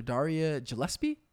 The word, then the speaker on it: Darria Gillespie: So today we have our interview with Dr. Darria Gillespie. Am I pronouncing that right? – Yeah.